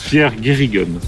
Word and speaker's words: Pierre Guérigen: On se demandait comment on va prononcer votre nom. – Pierre Guérigen.